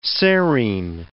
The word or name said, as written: serine